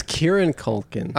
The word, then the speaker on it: Kieran Culkin: It's Kieran Culkin. – Oh my God. – I'm telling you, there's no second N in there.